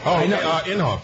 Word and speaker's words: Inhofe: Inhofe.